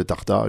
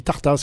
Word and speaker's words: Tartas: oui je cherche la commune de Tarta, je ne connais pas, vous connaissez, vous êtes d'ici, oui, je suis du coin, ma mère est du coin, je ne connais pas, comment ça s'écrit, T-A-R-T-A-S, ah je dis là oui, on dit Tartas quoi, Tartas, si vous dites Tarta, nous on ne connaît pas, je vois que c'est valable dans un sens et dans l'autre.